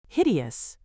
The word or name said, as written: hideous